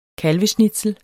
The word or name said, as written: kalveschnitzel